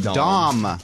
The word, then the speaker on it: Dom: I don't either. – Is it Don or Dom? – Dom. – Oh, with an M.